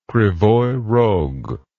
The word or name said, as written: Krivoi Rog